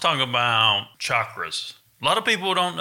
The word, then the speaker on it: chakras: You should pronounce it chakras, chakras. – Either way, I say chakras.